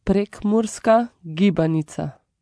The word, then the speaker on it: Prekmurska gibanica: Oh, Prekmurska gibanica.